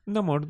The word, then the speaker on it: Namor: Namor, Namor atacó Wakanda y T'Challa sintió que tenía que enfocarse más en ser un rey que en ser esposo.